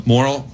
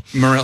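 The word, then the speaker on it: Morril: Blake Griffin has a hysterical clip on a comedy podcast with Mark Normand and Sam Merrill. Morrill. How do you say his last name? Morrill, I think. Morrill,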